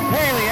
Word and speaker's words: Heili: Heili.